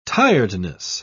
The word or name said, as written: tiredness